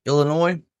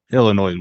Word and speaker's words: Illinois: Illinois is weird with putting an S at the end and not pronouncing it.